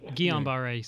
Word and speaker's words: Guillain-Barré: And now they are, and I'm going to mispronounce this, I'm sorry, Guillain-Barré. – That's not about right? – Guillain-Barré syndrome.